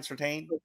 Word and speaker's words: Surtain: Ohio John asked, between Sam Madison, Pat Surtain, did I say it right? Surtain? – Surtain.